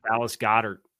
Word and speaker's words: Dallas Goedert: About Dallas Goedert. – It's an undercover pronunciation police officer, obviously. – Correct. Yes. So I'll say it three times the charm. Dallas Goedert.